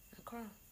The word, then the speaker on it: Accra: DLT in Accra, that was really good. DLT is always good though. – Accra. – There we go. – Oh, did I say it wrong? – Yeah, so English.